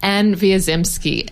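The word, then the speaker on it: Anne Wiazemsky: Anne Wierzemski.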